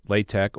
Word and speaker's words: LaTeX: LaTeX, pronounced LaTeX or alternatively, La as in lava and Tech as in technology, was initially invented as a typesetting language for mathematical notation.